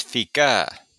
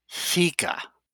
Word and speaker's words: fika: It's FICA. – Well, actually, it's pronounced more like FICA.